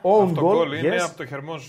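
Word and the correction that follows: Hermoso: στα 7. Ναι, Ερμόσο. Ερμόσο. – Το έτσι δεν διαβάζεται.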